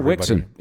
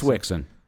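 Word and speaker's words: Wixson: Wixon. It's Wixon. – And that's not W-I-C-K-S-O-N, it's W-I-X-S-O-N.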